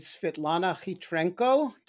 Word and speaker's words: Svitlana Khytrenko: Our guest today is Svitlana Khytrenko. Did I get that? Pronounce that within 100 miles of correct? – Yeah, you pronounced it perfectly.